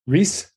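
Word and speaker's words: Rhys: I like Rise. Is that how you say his name? Reese? – I think Reese. Matthew Reese. – Oh, good. That's even better. Reese. Matthew Reese, yeah.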